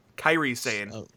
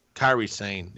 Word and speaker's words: Kairi Sane: Well, I'm sorry, did I pronounce your name wrong? – You not even pronounce it, you used the wrong letters. Kairi Sane. – Kairi Sane.